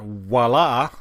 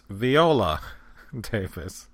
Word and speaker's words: Viola: Alright, so the actress is not Wala Davis, although it's spelled and pronounced, if you're French, I think, Wala. It's Viola Davis.